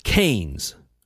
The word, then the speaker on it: Keynes: Actually, it says pronounced Keynes, according to Wikipedia.